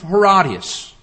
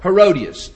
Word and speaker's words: Herodias: And I want to talk about a woman by the name of Herodias, or Herodias, as we sometimes pronounce her name.